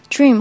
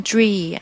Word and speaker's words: dream: dream 后面这个 m 不要把它丢掉，嗯、呃，个别同学可能会把它读成 dream 啊，m 省了，这就不好听。